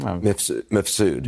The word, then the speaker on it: Mifsud: But those have nothing to do with Ms. Food. How do you say his name? – Mifsud. – Mifsud or any of that.